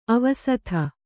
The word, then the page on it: ಆವಸಥ: āvasatha